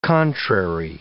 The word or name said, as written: contrary